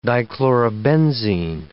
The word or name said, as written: dichlorobenzene